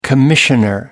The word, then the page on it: commissioner: American English